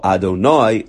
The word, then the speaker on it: Adonai: And again, Aleph, Dalet, Nun, Yud is, I'll say a pasuk. Baruch atah Adonai lamdeini chukecha. So that's the way one has to pronounce it.